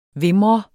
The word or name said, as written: VIMRER